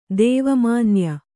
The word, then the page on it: ದೇವಮನ್ಯ: kannada